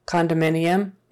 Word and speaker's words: condominium: But when we say the whole word, condominium, it kind of becomes an uh sound.